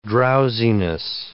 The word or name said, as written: drowsiness